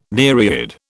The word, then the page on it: nereid: British English